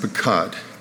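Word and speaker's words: pekad (פקד): The Hebrew word that is translated throughout the minor prophets and Jeremiah is PQD, often pronounced pekad, PQD.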